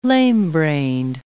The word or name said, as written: lamebrained